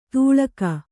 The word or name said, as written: ತೂಳಗ